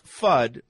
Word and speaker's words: FUD: It's pronounced FUD, like Elmer Fudd, and it stands for fear, uncertainty, and doubt.